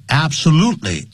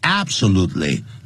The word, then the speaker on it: absolutely: absolutely 你查字典的时候很多字典会念成 absolutely 老外不是这么念的 absolutely 重音在第三个音节啊，